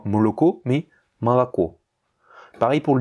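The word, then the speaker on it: Malako (молоко): Donc ce mot qui s'écrit Moloko a l'accent tonique sur le dernier O et donc il va se prononcer Malako.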